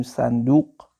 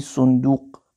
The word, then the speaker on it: صندوق: این رو ما معمولا با فتح تلفظ میکنیم میگیم صندوق ولی صندوق تلفظ صحیح عربیش هست جمعش میشه صنادیق